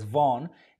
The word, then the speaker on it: Vaughan: So yes, this word is spelled and pronounced as Vaughn.